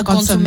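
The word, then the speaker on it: consommé: But the birria tacos are dipped into like a consomme. Mm-hmm. I said it right. – I'm so proud.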